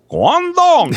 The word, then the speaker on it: Guangdong: And they're like, hey, man, you're not saying it right. And I was like, okay, Guangdong. And they're like,